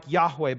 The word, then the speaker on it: Yahweh: Scholars think it's pronounced something like Yahweh, but we're not totally sure.